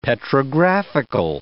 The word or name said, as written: petrographical